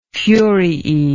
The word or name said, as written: furiae